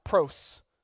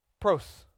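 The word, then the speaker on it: pros (πρός): That word with, it's pronounced pros. Pros. Like pro football. With an S.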